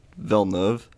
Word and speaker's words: Villeneuve: Is that how you say it? – I think it's Villeneuve.